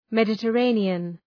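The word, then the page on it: Mediterranean: ˌmedətəˈreınıən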